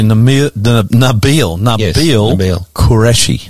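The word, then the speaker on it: Nabeel Qureshi: Nabeel. Quereshi.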